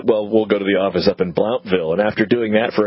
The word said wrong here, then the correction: Blountville: a couple of weeks, I had a judge stop me and go, it's pronounced Bluntville.